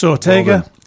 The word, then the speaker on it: Sawtega: Spell it. – S-A-W-T-E-G-A. That's Sortega.